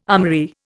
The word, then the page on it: armoury: Indian